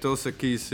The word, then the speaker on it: Dos Equis: Del Sequis? – Bodello? – Is what is that? How you say it? Dos Equis? – Dos Equis, yeah, that's probably the most American Mexican beer.